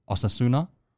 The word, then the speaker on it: Osasuna: Osasuna.